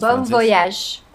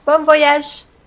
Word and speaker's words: Bon voyage: en así más Bon voyage. Bon voyage.